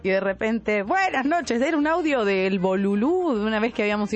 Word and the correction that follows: bululú: El Bululú. Lo dije mal, Bolulú.